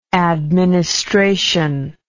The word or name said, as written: administrational